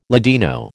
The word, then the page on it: Ladino: American English